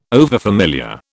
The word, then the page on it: overfamiliar: British English